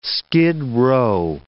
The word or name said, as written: skid row